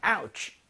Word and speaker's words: ouch: Ouch. Did you go and see a doctor? 이겁니다. – 아. – 네. 여기서 오가 아니라고요. out로 ouch. – 발음이 됩니다.